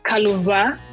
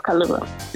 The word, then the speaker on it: Kaluwa: So, it's actually Kaluwa. But Kaluwa, like, okay. I have to type it out with a W. Then there's like a symbol on top of the W. But yeah, Kaluwa.